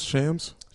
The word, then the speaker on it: Shams: Shams.